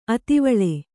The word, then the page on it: ಅತಿವೇಲ: ativēla